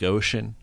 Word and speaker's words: ocean: Do you know that Pacific Ocean, every sea in that is pronounced differently? Pacific, so that's a soft C. – Oh. – Pacific, that's the hard C. And ocean, that's like a SH sound.